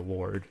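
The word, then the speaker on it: Lorde: How do you say it? Lord? It's just Lord.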